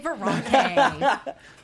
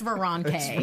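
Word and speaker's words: Veranke: She's, I never know how to pronounce it. Veronke. – Sure. – We'll say Veronke. I confirm this, it's Veronke. – Confirms from Geek and Sundry, Veronke.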